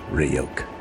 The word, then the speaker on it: Ryuk: It's pronounced Ryuk.